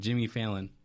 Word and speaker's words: Jimmy Fallon: And he sparked a new generation of people that hate Jilly Fallon. – Jilly Fallon. – I can't even say his name. Jimmy Fallon.